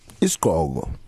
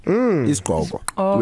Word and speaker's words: isigqoko: Isigqoko. Isigqoko.